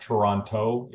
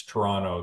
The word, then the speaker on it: Toronto: And just as an FYI, it's not Toronto, it's Toronto. There's only one T in Toronto. – Yeah. – Start with two, but you only pronounce one.